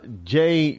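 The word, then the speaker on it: Jeh: Yet, you know, Department of Homeland Security Secretary Jay Johnson, I do believe it's pronounced J, J-E-H. I do remember I heard his name pronounced J.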